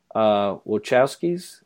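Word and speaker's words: Wachowski: Wojciechowskis. – Mm-hmm. – Wachowskis, isn't that how you say it? Wachowskis, there you go.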